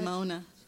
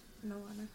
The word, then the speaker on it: Moana: Yes, the Should Mona. Moana.